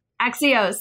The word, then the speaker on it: Axios: So this is published by Neil Irwin and is it Axis? Is that how you pronounce this? – Axios, thank you.